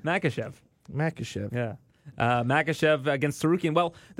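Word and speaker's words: Makhachev: Menchikov? – Yeah, the co-main event. – Oh, Makachev.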